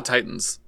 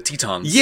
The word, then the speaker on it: Titans: They're not the Titans. They're the Tetons. – Yeah, so that's a very fucking stupid way. Yes. It's a transliteration because, you know, it's spelled Titans in Japanese, so you would pronounce it Tetons, but, like, it's clearly just the Titans.